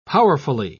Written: powerfully

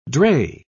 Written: drey

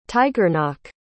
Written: tighearnach

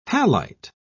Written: halite

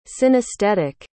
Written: synesthetic

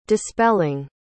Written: dispelling

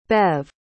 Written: Bev

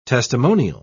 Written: testimonial

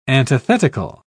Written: antithetical

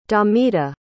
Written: damita

